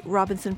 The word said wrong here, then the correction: Robeson: Professor. Robeson, that is, actually. It's Robeson, isn't it? I always mispronounce that.